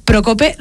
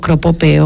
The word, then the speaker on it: Procope: De café Procope, ¿lo he dicho bien? – Vaya, la primera. – Le he confesado antes que siempre digo Cropope o cosas similares porque pues hay palabras que se me cruzan.